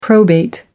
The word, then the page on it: probate: American English